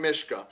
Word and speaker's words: Myszka: You can also find those same videos on my YouTube channel, which someone can just find by typing in Sean Mishka. And the last name is spelled M-Y-S-Z-K-A.